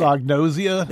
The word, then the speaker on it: anosognosia: I wrote it down, but I don't think I know how to say it. – Anosognosia? – There you go.